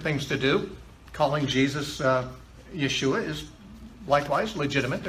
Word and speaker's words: Yeshua: Now, you noticed, of course, the name Yeshua was used. This is the Hebrew word for Jesus. It's the way the name Jesus would have been pronounced in Aramaic where he lived, at the time he lived.